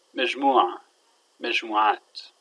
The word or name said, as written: magmu3a